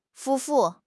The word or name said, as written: 夫妇